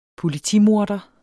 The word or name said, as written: politimorder